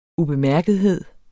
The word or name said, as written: ubemærkethed